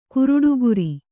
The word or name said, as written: ಗರುಡಿಕಾರ